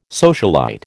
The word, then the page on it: socialite: American English